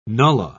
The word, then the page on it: nullah: British English